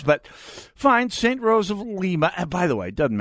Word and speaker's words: Lima: By the way, we are at St. Rose of I'm so I'm told it's pronounced Lima, as in Lima, Peru. I thought it may have been Lima, Ohio.